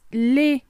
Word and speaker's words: les: Y en plural es les, que se escribe L-E-S. No se pronuncia la S al final. A veces sí la vamos a pronunciar, pero solamente si el nombre que sigue empieza con vocal.